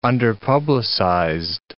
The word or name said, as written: underpublicized